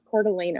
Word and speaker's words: Coeur d'Alene: How do you actually pronounce it in, like, you actually pronounce it Coeur d'Alene? – In Idahoan, it's Coeur d'Alene, Idaho.